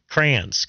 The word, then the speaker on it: crayons: It's crayons. I turned it. It's a two-syllable word. I turned into a one-syllable word. Crayons.